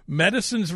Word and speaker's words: Medasin: Medicine's remix of Portugal the Man and their song Feel It Still. – By the way, I will also say I typed that up phonetically for you. It's spelled M-E-D-A-S-I-N.